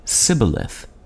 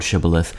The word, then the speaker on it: shibboleth: Gileadites pronounced the word shibboleth, but Ephraimites said sibboleth. Anyone who didn't pronounce the initial SH was killed on the spot. When English speakers first borrowed shibboleth, they used it to mean test phrase, but it has acquired additional meanings since that time.